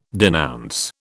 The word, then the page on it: denounce: British English